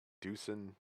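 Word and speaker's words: Dieuson: Deucen. It's D-I-E-U-S-O-N.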